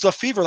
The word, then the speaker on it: Lefebvre: Apparently it's pronounced LeFay. – L-E-F-E-B-V-R-E. – I guarantee you I'm not wrong about this. It's LeFevre, like future Cub manager and former Dodger infielder Jim LeFevre.